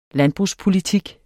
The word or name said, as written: landbrugspolitik